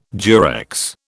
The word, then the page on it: Durex: British English